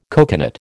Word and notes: coconut: American English

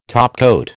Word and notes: topcoat: American English

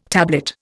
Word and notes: tablet: Indian

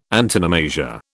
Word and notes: antonomasia: British English

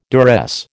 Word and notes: duress: American English

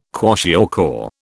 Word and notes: kwashiorkor: British English

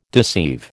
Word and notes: deceive: American English

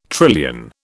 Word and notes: trillion: British English

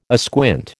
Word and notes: asquint: American English

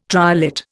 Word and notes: triolet: Indian

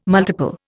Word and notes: multiple: Indian

